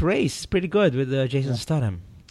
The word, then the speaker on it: Jason Statham: So yeah, we got to see Jason Statham. Is that how you say it? – Yes.